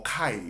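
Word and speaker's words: kai (καί): So the word there and is the word ki in the Greek, or kai, yeah, k-a-i, right? Pronounced k-a-h-w-e.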